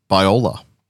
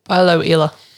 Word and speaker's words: Bioela: Biola. Biola? Biola. How do you pronounce it? – Bioela. – Yeah, that sounds better than what I did.